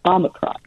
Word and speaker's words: Omicron: That's Well, Bob, you know, I actually debated which way to say it, and you know, the word Omicron is, there's two O's in the Greek alphabet, right? There's a long O, Omega, and a short O, Omicron. And if you say a short O in English, we say short O with the O. And so if you want to use the, you know, the equivalent in Greek, you would say Omicron, not Omicron.